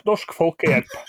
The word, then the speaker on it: Norsk Folkehjelp: So you are a member of, it's translated as Norwegian People's Aid, so I'm going to try and pronounce this. Norsk Folkehjelp? Folkehjelp? – That was quite close. Norsk Folkehjelp.